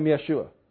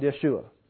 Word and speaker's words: Yeshua: Now, Joshua, in the day that Joshua lived, you would have pronounced it Yehoshua. But in the day that Jesus lived, by that time that name had changed enough that it was called Yeshua.